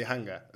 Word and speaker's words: Hanga: In Hungary, we say Hanga. – Hango. – Yeah, okay. – But here in Spain, for example, they say Hanga.